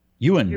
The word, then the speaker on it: Ewan: Ewan McGregor.